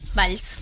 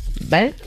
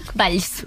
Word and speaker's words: Valls: ¿Valls? – Valls. – ¿Vale? – Valls.